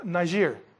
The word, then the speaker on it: Niger: Now, in that church, besides these and Barnabas, there was Simeon, this guy who was called Niger. That's how you would pronounce that.